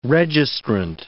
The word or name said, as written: registrant